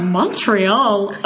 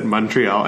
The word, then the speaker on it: Montreal: I also, it's so refreshing to hear someone pronounce it Montreal and not Montreal. – Yeah. – Literally, every time anyone says it in the show, they say Montreal.